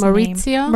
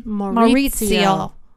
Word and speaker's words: Maurizio: And I forget how to pronounce the husband's Maurizio? – Name.